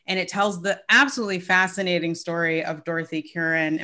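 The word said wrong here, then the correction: Kirwan: Am I saying that correctly? Curran or? – Kerwin. But Kerwin. I'm Southern, so we often mess up every name.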